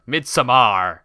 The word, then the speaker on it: Midsommar: I love how you really emphasize that it ends with an AR. Midsommar.